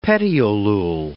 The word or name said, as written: petiolule